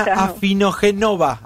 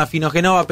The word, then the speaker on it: Afinogenova: Era Ina Afinogenova, ahora sí lo dije bien, mirá, como ya no escucha lo digo bien. Ina Afinogenova, periodista de Raya Today y voluntaria de la vacuna Sputnik B que pasaba en Crónica Anunciada.